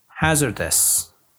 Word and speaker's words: hazardous: Yes, the word was hazardous. Hazardous. Please pay attention to the pronunciation. The position of stress is at the beginning of the word.